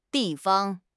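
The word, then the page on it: di4 fang1: tone 4; tone 1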